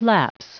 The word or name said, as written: lapse